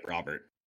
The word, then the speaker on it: Robert: Yeah, it's just straight up Robert.